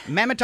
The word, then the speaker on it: Mehmet: Mehmet Oz.